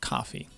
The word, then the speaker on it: Coffey: And Michael Clarke Duncan as John Coffey. Like the drink, but not spelled the same.